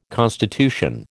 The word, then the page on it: constitution: American English